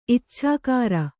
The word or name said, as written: ಇಚ್ಛಾಕಾರ